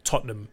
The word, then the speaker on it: Tottenham: Men det udtales Tottenham. Så T-O-T-N-U-M, eller ja, det er nok det nemmeste. Tottenham. Du sluger H-A-M og bare siger Tot. Tottenham.